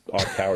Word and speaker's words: ICAUR: ICAR, which is spelled I-C-A-U-R, but that's ICAR is how they were pronouncing it.